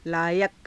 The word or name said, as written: laayaka